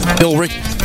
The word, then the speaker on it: Billerica: Billerica. There's an E in there. – Billerica. – Billerica, maybe? – Billerica. – Oh, that makes sense. Just leave out the E.